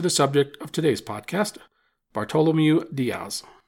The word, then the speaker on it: Bartolomeu Dias: I want to start out by saying that Diaz is often referred to in his anglicized pronunciation, Bartholomew Diaz. I'm going to try and stick to the Portuguese pronunciation, but know that I grew up calling the guy Bartholomew, so forgive me if I mess up the name during the podcast.